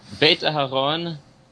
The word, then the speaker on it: Beit Aharon: My name is Noah Beit Aharon. I publish under the name N.S. Dolkart. – Spell Beit Aharon for those. – Beit Aharon in English is spelled B-E-I-T hyphen A-H-A-R-O-N.